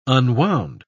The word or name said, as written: unwound